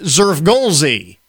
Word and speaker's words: Xfrgolszzzxy: It is spelled, are you ready for this? X-F-R-G-O-L-S-Z-Z-Z-X-Y. Pronounced Zerfgolsky.